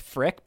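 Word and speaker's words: Frik: Him Babu Freak, by the way, because I think it's pronounced Frick, but now I'm imagining it's like Babu Freak, like, get freaky on the dance floor.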